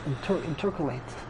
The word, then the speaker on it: intercalate: Intercalculate. – No, it's not calculate. It's not calculate. It's intercalate. Intercalate.